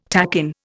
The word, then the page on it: takin: Indian